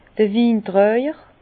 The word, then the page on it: d'r Wintraijer: Bas-Rhin; Herrlisheim